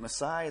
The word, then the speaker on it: Maasai: Well, so off he went to the Maasai tribe. I think I'm saying it right. Maasai, is that how you say it? Good. Thank you, Andy. It's good to have an African here who can tell us how to do this.